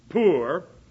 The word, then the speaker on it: Pur: And the word P-U-R there, which is usually pronounced poor, it has three different pronunciations.